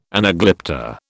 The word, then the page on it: anaglypta: British English